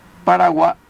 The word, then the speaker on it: Paraguay: palabra guaraní, cuyo sonido gutural final no podían pronunciar los españoles emitiéndolo como I latina, por lo que quedó con el nombre de Río Paraguay, pero escrito curiosamente con Y final, fonema gutural para el guaraní, Paraguay.